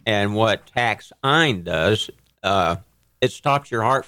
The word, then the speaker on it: taxine: Taxine. – So how do you spell it? T-A-X-I? – T-A-X-I-N-E. – It's just that it is, you say it differently.